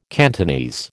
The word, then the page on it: Cantonese: American English